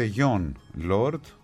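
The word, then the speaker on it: Jon Lord: Ο Κώστας, το σωστό είναι προφέρεται γιον Lord, και τον έχει δει, αυτό είναι το σημαντικό, ο Κώστας, στο live στο Royal Albert Hall στα 40 χρόνια που έμεινα Αγγλία.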